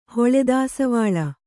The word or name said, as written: ಹೊಳೆದಾಸವಾಳ